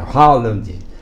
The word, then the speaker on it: Haaland: Mas se eu fosse escrever para o português falar, escrevia Holland com H, porque é lá do fundo da garganta. Haaland.